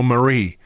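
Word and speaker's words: Marí: Well, he's got the accent is on the end. It's on the I, so it would lead you to believe it's Pablo Marie.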